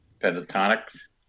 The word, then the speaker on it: Pentatonix: Pentatonix.